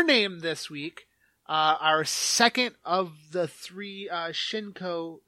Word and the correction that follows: Shinkai: Did I say that right? – Makoto Shinkai. – Shinkai. The Shinkai films are second of our trilogy that we are reviewing.